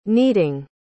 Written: kneading

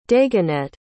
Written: dagonet

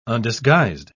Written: undisguised

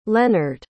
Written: lennard

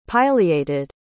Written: pileated

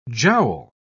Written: jowl

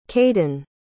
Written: cayden

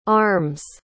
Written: arm's

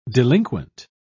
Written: delinquent

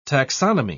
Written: taxonomy

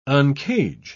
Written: uncage

